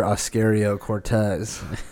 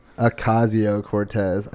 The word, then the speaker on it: Ocasio-Cortez: I call her Oscario Cortez. – I don't know how to pronounce it either. – I don't know. AOC, what is it? Her name is Ocasio Cortez. I think Oscario is pretty good.